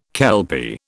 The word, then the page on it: kelpie: British English